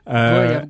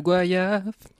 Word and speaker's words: goyave: Et d'ailleurs, la conclusion, c'est prononcer Goyave comme voyage. Merci. – Tout à fait. – Goyave, Goyave.